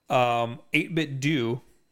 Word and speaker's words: 8BitDo: 8-bit do, which is how you pronounce it. – Okay. – Because everybody always yells. – Yeah, at me. They always say do.